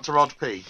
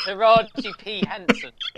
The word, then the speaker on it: Taraji P. Henson: Is it Taraji, not Taraj P? – Yeah, it's Taraji P. Taraji P. Henson.